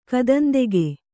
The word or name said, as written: ಕದಂದೆಗೆ